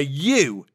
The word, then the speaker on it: ewe: A ewe is the word for a female sheep, and it's spelt E-W-E. But it's pronounced in the same way as you, as in you and me.